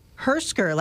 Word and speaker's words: Hersker: Hersker, like it's spelled, right, John? – That's correct.